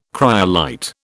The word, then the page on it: cryolite: British English